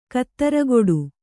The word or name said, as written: ಖುದ್ರಗೇಡಿ